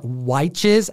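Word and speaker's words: Wytches: If you've read Witches or Witches, as Nick from Key Collector Comics calls it, because it's spelled with a Y, comment down below.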